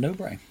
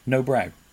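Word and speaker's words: Nobrow: How do you pronounce No Brow? – Because I heard someone. – No Brow. Because I heard somebody call it No Bro the other day.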